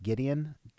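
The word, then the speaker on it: Gwydion: But I'll start out with The Math of War by, I think it's pronounced Gideon. It's G-W-Y-D-I-O-N.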